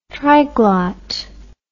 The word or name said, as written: triglot